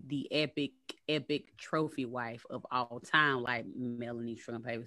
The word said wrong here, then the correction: Melania: even know how to say the girl Melania.